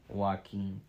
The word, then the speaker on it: Joaquin: Yeah, I don't know how to pronounce his name. – Joaquin, yeah. – Yeah, there you go, you got it.